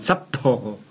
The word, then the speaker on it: Sapporo: Nein, die Betonung muss auf das Doppel-P hier sein. Sapporo.